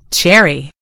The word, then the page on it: cherry: American English